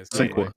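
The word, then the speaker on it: Sinqua: Is it Cinqua? – Yeah, Cinqua. – Like Cinqua Walls.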